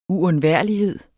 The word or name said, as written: uundværlighed